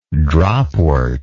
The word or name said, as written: dropwort